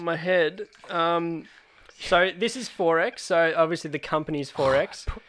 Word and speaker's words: XXXX: So it's X's, it's XXXX, pronounced 4X.